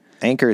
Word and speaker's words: Anker: Anker is spelled A-N-K-E-R, kind of like Akron, but different.